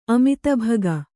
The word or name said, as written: ಅಮಿತಭಗ